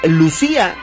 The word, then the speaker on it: Lucía: Es Lucía, porque muchos le ponen acento en la I, Lucía.